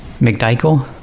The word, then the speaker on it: McDichael: It's S-L-E-V-E. – And your last name, Sleeve? – McDichael. – I'm sorry, can you spell that as well? – M-C-D-I-C-H-A-E-L.